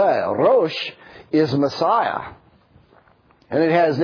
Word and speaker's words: Rosh: If you hear the middle syllable, Rosh, Berosh, Bereshith. Say that with me. Bereshith. Very good. The middle word is Rosh. R-O-S-H.